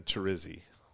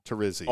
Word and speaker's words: Terrizzi: I mean, growing up, it's always been Terrizzi. Terrizzi. – Oh, I'm close. I'm good. – You're super close. In Italy, it's Terrizzi, you know, like pizza. Terrizzi.